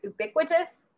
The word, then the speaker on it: ubiquitous: I mean, it's just become more refined and more, I never say this word right, ubiquitous. You can tell what I mean. – No, you said it right.